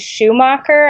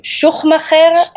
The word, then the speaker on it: Schuchmacher: Yeah, my last name is, the easy way is Schuchmacher. I like to say that the middle CH is silent, but the authentic German way is Schuchmacher, and no one can say it, so I don't try.